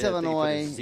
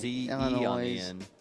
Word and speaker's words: Illinois: Did he pronounce the S? – It's Illinois. Illinois.